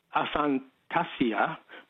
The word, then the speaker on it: aphantasia: And before I go, just quickly, the proper pronunciation of aphasia and aphantasia, which is the inability to visualize things in your brain, but that's not a subject altogether, would be like aphasia. Aphasia and aphantasia.